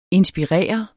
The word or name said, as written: inspirere